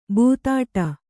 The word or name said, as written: ಬೆದಡೆ